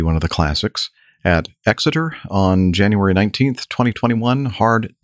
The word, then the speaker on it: Exeter: I'm saying that right, am I? Exeter? – Yes, you are.